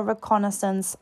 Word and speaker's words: reconnaissance: And the National Reconnaissance Office, the NRO. I'm very happy that I pronounced reconnaissance correctly.